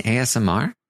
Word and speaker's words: ASMR: He's like, "I'm doing some asthma right now with my chips," and Day's like, "What? – Do you mean ASMR?" It's like, oh, is that how you say it?